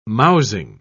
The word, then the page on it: mousing: British English